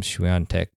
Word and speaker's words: Swiatek: Just think about Nadal, think about Swiatek, which I never would have guessed that you pronounce Iga's name Swiatek.